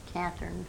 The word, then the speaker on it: Catherine: Mary Catherine Lucas, L-U-C-A-S. – How do you spell your Catherine? – With a C, C-A-T-H-E-R-I-N-E.